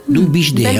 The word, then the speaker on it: Du Bisch de Held: Du Bisch de Held. – Belle prononciation.